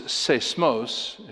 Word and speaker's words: seismos: If you wrote it in English letters, it'd be S-E-I long O. But the noun is seismos. If you wrote it in English letters, it'd be S-E-I-S-M-O-S.